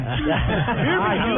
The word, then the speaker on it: Birmingham: Birmingham.